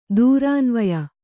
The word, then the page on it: ದೂರಾನ್ವಯ: durānvaya